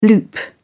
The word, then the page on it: loop: British English